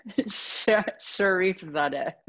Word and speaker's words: Sharifzadeh: Sharifzadeh.